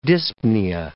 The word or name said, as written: dyspnea